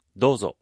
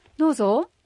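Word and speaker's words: دوزو (どうぞ): دوزو دوزو دوزو استمعوا ورددوا دوزو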